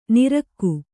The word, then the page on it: ನಿರಕ್ಕು: kannada